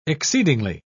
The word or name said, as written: exceedingly